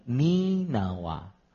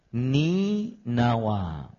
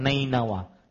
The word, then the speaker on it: Ninawa: Ninawa, para ikhwah yang dirahmati oleh Allah Subhanahu wa taala, membacanya, cara membacanya adalah bikasri awwali Ninawa. Wasukuni thani, ni nawa. Artinya dikasrahkan huruf awalnya dan huruf yang keduanya diberikan sukun. Ya. Begini cara orang Arab dahulu agar tidak salah baca. Dijelaskan, diberikan kasrah pada huruf awalnya dan diberikan sukun pada huruf keduanya. Berarti cara membacanya Ninawa. Jadi terjemahannya keliru, bukan Nainawa, Ninawa.